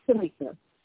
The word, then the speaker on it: Tamika: It's Tamika. Oh, absolutely. It's T-A-M-I-K-A.